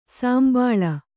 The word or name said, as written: ಸಂಭಾಳಿ